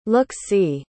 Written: look-see

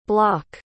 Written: blok

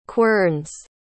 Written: querns

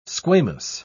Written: squamous

